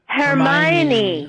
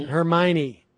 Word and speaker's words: Hermione: Milo was a Hermione Granger or Ermine Granger, whatever, from Hermione. – Hermione.